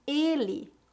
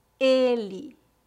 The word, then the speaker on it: ele: Ela com E aberto e ele com E fechado. Repita depois de mim. Ela, ela, ele, ele.